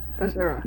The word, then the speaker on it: Missouri: Missouri.